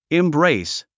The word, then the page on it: embrace: imbréis; インブレィス